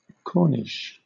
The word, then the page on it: corniche: British English